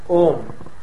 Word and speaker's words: Om: And this is the Om, and the Om only, because these three letters A, U, Ma, pronounced in combination as Om, may well be the generalized symbol of all possible sounds.